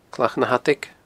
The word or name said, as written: Clach na h-Adaig